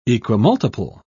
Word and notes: equimultiple: British English

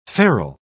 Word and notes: ferule: British English

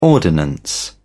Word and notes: ordinance: British English

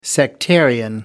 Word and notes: sectarian: American English

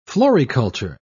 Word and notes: floriculture: British English

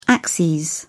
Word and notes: axes: British English

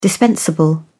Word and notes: dispensable: British English

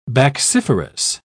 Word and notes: bacciferous: British English

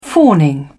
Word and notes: fawning: British English

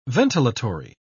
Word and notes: ventilatory: British English

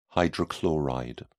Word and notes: hydrochloride: British English

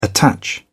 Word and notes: attach: British English

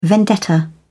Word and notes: vendetta: British English

